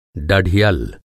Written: डढियल

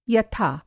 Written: यथा

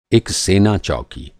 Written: चौकी

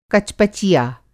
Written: कचपचिया

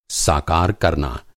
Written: साकार

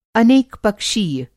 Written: अनेक पक्षीय

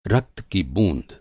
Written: रक्त

